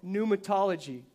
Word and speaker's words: pneumatology: but there's a study called pneumatology. Pneumatology, isn't that fancy? And all that means is this the study of the Holy Spirit. There's, there's volumes and volumes of books. Pneumatology, I'll spell it P N E U M A Pneumatology I,